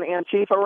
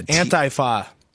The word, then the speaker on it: Antifa: Have you ever been to an Antifa rally? – I don't even know. – I think you're pronouncing that wrong. It's Antifa.